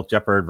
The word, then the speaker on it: Jeppard: So Jeppard, which rhymes with leopard.